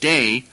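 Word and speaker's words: day (δεῖ): The Greek word DEI, which then is pronounced day,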